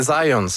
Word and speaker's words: Zajonc: Non, c'est Zajonc. – Ouais, OK. – J'ai-tu pas dit Zajonc? – Vous avez dit plus Zajonc, mais. – J'ai prononcé un petit peu à l'anglais. J'ai prononcé mon polonais à l'anglais.